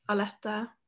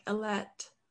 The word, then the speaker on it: Aletta: But yeah, in Norwegian, it's pronounced Aletta. – Aletta. – But in English, people pronounce it like Alette, Alette.